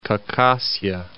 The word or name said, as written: Khakassia